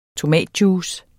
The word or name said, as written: tomatjuice